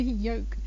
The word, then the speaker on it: yolk: So for example, if you have an egg, the egg has a yolk, so it's not the yolk, it's the yolk. And if you're talking about folk, either folk music or folk as in people, then you don't pronounce it there either.